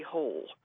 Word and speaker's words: Harry Hole: And he's created one of the great, I think, modern detective characters in fiction, Harry Hole. And yes, it's pronounced Hole, not Hole.